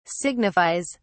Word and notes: signifies: British English

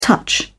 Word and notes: touch: British English

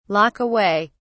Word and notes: lock away: British English

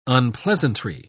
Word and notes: unpleasantry: British English

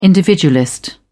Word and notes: individualist: British English

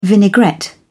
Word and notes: vinaigrette: British English

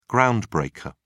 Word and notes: groundbreaker: British English

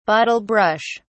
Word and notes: bottle brush: British English

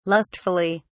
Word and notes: lustfully: British English

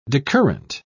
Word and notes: decurrent: British English

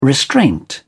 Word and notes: restraint: British English

